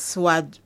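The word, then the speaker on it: Suad: Yes, Swad Rijik. Yes, Swad Altaib Rijik.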